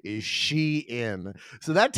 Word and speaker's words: Shein: Oh, Mary, it is Shein. – Jesus. – It's Shein.